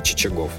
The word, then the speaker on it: Чичагов: 300 лет назад в этот день родился полярный исследователь, флотоводец, адмирал Василий Чичагов. Именно так ставят ударение российские словари.